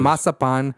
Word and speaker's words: marzipan: Right, that's it in Spanish, but like everyone else in English, it's pronounced marzipan.